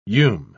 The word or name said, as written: ume